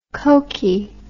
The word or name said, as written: cokie